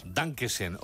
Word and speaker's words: Dankeschön: Dankeschön, o como se diga.